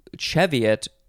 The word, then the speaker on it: Cheviot: And, you know, the pronunciation Cheviot with the C-H sound is British, so I'm guessing the Cheviot Hills are in England somewhere.